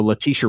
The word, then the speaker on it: Letitia: So several months ago, Letitia Wright, or I don't know how to say her first name, but I believe that's right.